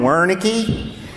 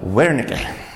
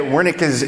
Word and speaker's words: Wernicke: So in Texas, we call him Wernicke. But since I'm in Germany, I figure I'd try to pronounce it right. So Wernicke. Is that right?